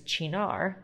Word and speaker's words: Cynar: And it's named the Art of Choke because it contains Chinar, that's spelled Cynar, which is an Amaro that is made from artichokes.